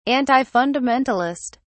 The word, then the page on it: antifundamentalist: British English